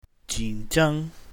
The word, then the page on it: jin3zhang1: mandarin